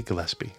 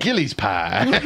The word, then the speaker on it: Gillespie: Say it right. Dizzy Gillespie. – Gillespie is how I would say it. – Gillies pie. Oh, shoot.